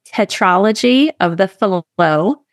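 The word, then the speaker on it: tetralogy of Fallot: Yeah, so we say tetralogy of the fallot with pulmonary atresia and MAPCAS. I'll try and say that five times or more. We're quite used to saying it. – Oh, okay, so it's a silent T, fallot with pulmonary atresia and MAPCAS.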